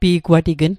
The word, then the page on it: pi'gwatign: Mi'gmaq